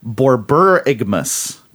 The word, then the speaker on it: borborygmus: He says it's pronounced Borborygmus.